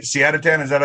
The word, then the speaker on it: Ali Siadatan: I am joined with a very special guest, Ali Siadatan, right? Siadatan, is that how you say it? – That's it. – You got it right.